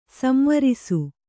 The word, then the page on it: ಸಂವರಿಸು: samvarisu; kannada